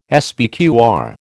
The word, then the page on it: SPQR: American English